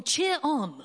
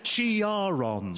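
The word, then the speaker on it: Ciarán: The country is braced for Storm Cheer-on. – Storm Chiaron. – Storm Chiaron. – Storm Chiaron. – The consensus seemed to be that it was Storm Chiron, which was unfortunate as thousands of Labour activists immediately switched off.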